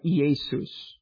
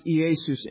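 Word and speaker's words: Iesous: And so in the Greek, the name Jesus is pronounced like Iesus. Right? It's pronounced Iesus in the Greek.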